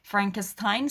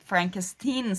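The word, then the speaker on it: Frankenstein: لفظ فرانکشتاین به انگلیسی فرانکستاین تلفظ میشه. آره. انقدر این چیز فردریک از این نسبت فامیلی ناراحته که از دانشجوهاش اول فیلم میخواد که اصلا منو فرانکستاین صدا نزنین، فرانکستین صدا بزنین، و اینو در طول فیلم چندین دفعه در واقع تکرار میکنه، که خیلی کمدیای جالبی اطراف همین این تفاوت تلفظ ایجاد میشه.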